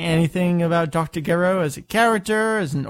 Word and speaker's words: Gero: Okay, so in the Japanese version, it says Doctor Gero. Gero. Gero in the English version, they said Giro, like different emphasis, different pronunciation.